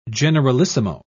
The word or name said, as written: generalissimo